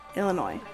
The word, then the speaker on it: Illinois: When Congress admitted Illinois, oh my god, I always forget how to say it. Illinois. No S.